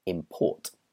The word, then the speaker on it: import: The next example is import. The verb is to import. And again, the stress goes on the second half of the word, import. For example, to import fruit from other countries. But the noun is imports. The stress is on the first half, a cheap import from another country.